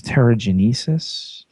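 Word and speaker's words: Terrigenesis: Needs a little help on the pronunciation there. It's pterogenesis.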